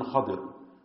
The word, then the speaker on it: Khidr: Khadr alayhi salatu wassalam, pronounced as Khidr and Khadr, with the fatha and with the kasra.